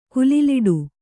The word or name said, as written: ಕುಲಿಲಿಡು